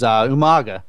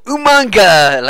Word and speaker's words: Umaga: Number 3 on the list is Umaga. Yeah, him too. – It's your regal, you know, Umanga. Like, well, that's not how it's pronounced, but sure. Just to say that British people can't say Umaga.